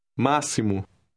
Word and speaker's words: máximo: In this word, the X is pronounced like the S in the English word see. Listen and repeat word 8. – Máximo.